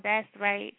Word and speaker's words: That's Right: That Right. That's Right. I said That Right. It's called That's Right.